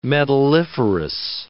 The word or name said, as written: metalliferous